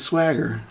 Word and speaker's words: Swaggart: Do you want to call him Swagger? – No. – Jimbo? – Jimbo, Jim. – Swagger, that name, just Swaggart or Swagger? – It's Swagger. That's how you say it, but am I close enough to the mic, guys? – I don't know.